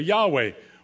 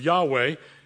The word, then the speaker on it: Yahweh: Our pronunciation of Yahweh is a reconstruction, and we don't know absolutely if it's correct. It comes from the consonants of Yahweh and the vowels of Adonai, another name for God in the Old Testament.